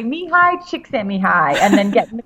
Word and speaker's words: Mihaly Csikszentmihalyi: I read it, Mihaly Csikszentmihalyi, but apparently the L is silent. So I had to drive all the way back down to LA just to say his name in the microphone properly.